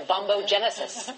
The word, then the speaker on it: bombogenesis: All right, Professor Kaku with the bombogenesis.